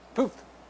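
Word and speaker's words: Puth: iHeartMedia looks to make a splash by bringing in Charlie, how do you say that? Hoth? Pooth? Pooth. Pooth, thank you.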